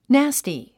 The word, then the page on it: nasty: nǽsti; ナァスティ